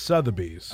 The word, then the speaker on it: Sotheby's: It's Sotheby's.